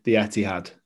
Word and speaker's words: Etihad: All games played in the Etihad. I'll say it right this time. – Yeah. Well done. Better than last time. But yeah, Etihad, like I said, I've actually been there.